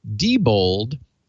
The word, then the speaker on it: Diebold: And then also adding to that, Midwest Guru said, Diebold, and I'm now working to pronounce it correctly, is a Germanic name. When E and I are together, pronounce the second one. He also used the example of Einstein and Diedrich, where again, D-I-E-T-R-I-C-H, Diedrich.